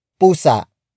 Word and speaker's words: pusa: Pusa. Pusa. Pusa is spelled as P-U-S-A.